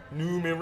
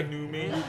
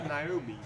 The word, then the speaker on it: Noomi: The Tom Hardy, Gary Oldman, Noomi Rapace. – Yes, good cast. – Is that how you pronounce it? – I'm calling her Noomi. Yes, Noomi. That's what she told me.